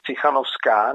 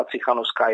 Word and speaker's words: Cichanovská: No a jestliže někdo takto užívá u nás tuto vlajku, včetně toho, že tady byla paní Cichanovská, nebo Cichanovská je staroněmecké jméno, Cichanovská je to původní běloruské jméno,